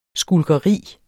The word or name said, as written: skulkeri